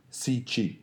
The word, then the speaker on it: Cixi: It's spelled C-I-X-I, okay. But I believe it is pronounced Cixi.